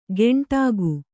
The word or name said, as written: ಗೆಂಟಾಗು